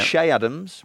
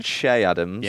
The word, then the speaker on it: Che Adams: Shea Adams, rather than Che Adams.